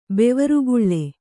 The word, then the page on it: ಬವರಿಗೊಳು: kannada